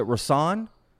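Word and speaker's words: Rahsaan: Rasan Roland Kirk. Is it Rasan? – Yeah, you got it right. A lot of people mispronounce his name, but that's it exactly.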